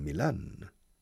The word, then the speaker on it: Milan: It's interesting that in Spanish, the name of the important city in northern Italy is called Milan. Milan. Stressing the second syllable, Milan. However, the football club is called El Milan. Changing the pronunciation.